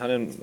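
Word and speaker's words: Hunnam: vor allem natürlich hervorheben ist halt Charlie ähm, Hannem. Spricht man den so aus? Ja. – Ja, Charlie Hannem, äh, Charlie Hannem und, und Colin Farrell auch.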